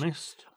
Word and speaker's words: honest: Una cosa interessante è quanto è importante l'H prima di tutto, perché se una parola inglese c'ha l'H all'inizio, ci sono delle eccezioni dove c'è scritto l'H ma non si pronuncia, ma sono tipo tre. C'è honor, hour, che sarebbe ora, e poi c'è... What's the other one? There are three, right? Three words in English. – Honest. – Honest, honest. Ecco, onesto.